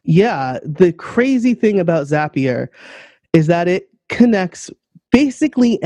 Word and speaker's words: Zapier: It's pronounced Zapier. Zapier makes you happier, um, and it it connects apps.